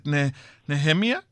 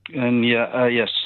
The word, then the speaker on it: Nehemiah: Is it Nehemia? – Nehemiah Call Initiative. – Nehemiah Call. – That's right.